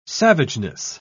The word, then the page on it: savageness: British English